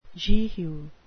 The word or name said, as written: jehu